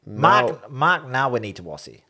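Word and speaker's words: Nawaqanitawase: Can I just point out that I've been practicing my Mark Wanitawasi? – I think you're missing a syllable or two there. – Oh, shit. Mark Nawanitawasi. – Yes, there you go.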